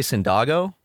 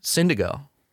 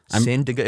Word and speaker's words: Syndigo: Yeah, Syndigo. Syndigo. It rhymes with indigo.